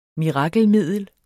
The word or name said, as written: mirakelmiddel